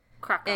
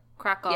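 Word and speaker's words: Kraków: Krakow. – In what? – Yeah. Krakow.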